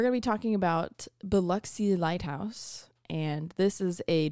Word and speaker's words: Biloxi: Biloxi. – Biloxi. – B-I-L-O-X-I.